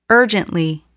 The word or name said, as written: urgently